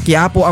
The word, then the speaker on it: Quiapo: Nahirapan kasi akong i-pronounce yung name niya kahapon. Quiapo Ega kasi dun sa sa post. Ano pala, Quiapo ang pronunciation nung typhoon natin ngayon mga kapatid.